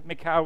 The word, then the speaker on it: Michal: And I never get the pronunciation right. Michal, Michael. What do we go with today? We'll go with, thank you, I've got the word here. Michal, we'll go with Michal today.